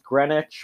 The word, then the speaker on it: Greenwich: Like you know, it's like how like it looks like it's spelled Greenwich, but it's pronounced Greenwich, or like Norwich is Norwich, or Warwick is Warwick.